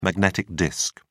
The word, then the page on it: magnetic disk: British English